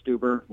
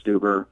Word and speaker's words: Steuber: Yeah, you can check me out on Twitter at Chris Stuber. Last name is spelled S-T-E-U-B-E-R. You can also get me on Instagram at Chris Stuber.